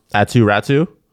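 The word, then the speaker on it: Aatu Räty: and Aku Ratu. Mm-hmm. Am I saying that correctly? I believe. – Is it Aku or Atu? – Atu Ratu. Yeah,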